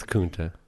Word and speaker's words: Kunta: It's Kunta. Like Kunta Kinte.